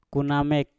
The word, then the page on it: ku namek: Mpur